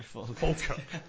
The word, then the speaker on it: Falkirk: That he played for Falkirk. – Falkirk. – Falkirk. – Falkirk. Falkirk. Thank you. It's not often I get the chance to correct other people's pronunciations.